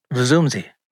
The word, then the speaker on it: Vusumzi: V-U-S-U-M-Z-I. How the fuck do I say that? – Vuzumzi Make.